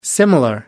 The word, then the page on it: similar: American English